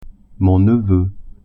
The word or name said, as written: neveu